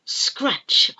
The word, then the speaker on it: scratch: But if not, we have a specialist shop called a butcher, B-U-T-C-H-E-R, where they sell all kinds of meat. Butcher. Butcher. Scratch. Oh, I'm going to scratch my head. It's itching. I'm going to scratch my head. S-C-R-A-T, silent T, C-H, scratch, scratch.